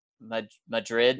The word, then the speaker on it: Madrid: And one thing, but there's a small village outside of Santa Fe called Madrid, which is pronounced like Madrid, Missouri. – New Madrid, Missouri. – Oh, New Madrid, Missouri. – That's how they say it there. Yeah. – With the emphasis on the first syllable and the Madrid, the capital of Spain.